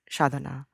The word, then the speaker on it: sadhana: In my language, Bengali, we pronounce it sadhana.